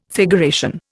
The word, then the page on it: figuration: Indian